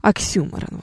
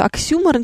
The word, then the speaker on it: оксюморон: Обратите, кстати, внимание на ударение в этом слове, потому что многие полагают, что он оксюморон, думая, что слово имеет французское происхождение. Но нет ничего подобного. Слово происхождение греческое, поэтому ударение будет на букву «Ю».